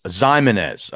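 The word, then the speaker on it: Ximines: All right, I've got a pronunciation question for you. The strong side starting linebacker for the Giants out of Old Dominion. Give me his name. – O'Shane Zimenez. – Zimenez. Okay. – Zimenez. Everybody, okay.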